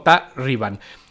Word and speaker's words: Ta-2-Riban: Es el grupo Ta-2-Riban, es como se escribe, se lee algo así como Ta-Riban.